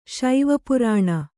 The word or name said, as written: ಶೈವಪುರಾಣ